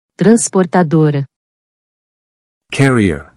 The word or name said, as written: Transportadora